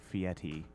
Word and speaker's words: Fieri: F-I-E-R-I. On Google, it says it's pronounced as Fieri or Fieti. – With a T? – Yeah. With either, you can either do it like, it's like a soft T, so it kind of sounds like a D. So it's like halfway between Fieri and Fieti.